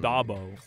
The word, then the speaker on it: Dabo: Dabo.